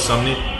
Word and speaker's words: Sumney: Oh, Moses Sumney? – Yeah, Sumney.